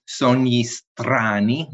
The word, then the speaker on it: Sogni Strani: Well, you didn't do too bad. Sogni Strani, maybe a little roll with the R, but that takes practice for most Americans to perfect.